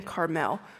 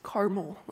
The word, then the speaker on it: Carmel: Well, his home is in Maon, and his business is in Carmel. And this is not pronounced Carmel, like the candy.